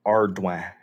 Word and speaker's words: Ardoin: Ardoin. So, R, like a pirate.